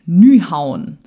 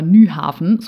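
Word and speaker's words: Nyhavn: Nummer eins: Nyhauen. Und nicht etwa Nyhafen, so schreibt man es nämlich, aber offenbar spricht man es Nyhauen aus.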